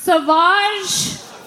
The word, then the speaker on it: Sauvage: Johnny Depp has a fragrance called Savage, pronounced Sauvage.